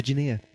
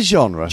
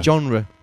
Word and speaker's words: genre: Certainly covers the genre. – From Do you mean the genre? – Genre. Yeah, sorry. Genre.